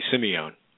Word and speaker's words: Simeone: Yes, you've got it perfectly, Simeone.